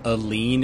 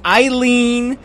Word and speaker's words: Aline: Wait, you're surprised that a person named Aline is mispronouncing words? Her entire name is mispronounced. It's Eileen. There's Aline. – No, there's an A there. It's A-L-I-N-E.